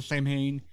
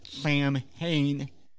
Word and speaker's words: Samhain: The band is typically pronounced Samhain, though when the word occurs in song lyrics, Danzig pronounces it Samhain, in conformity with the general accepting pronunciation of the holiday.